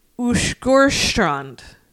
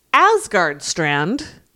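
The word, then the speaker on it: Åsgårdstrand: I believe it is Ushgorstrand. Wow. but it looks like Asgard Strand.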